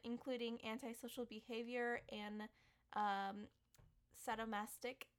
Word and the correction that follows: sadomasochistic: Did I say that right? Sadomasochistic. Sadomasochistic. – yes, sadomasochistic.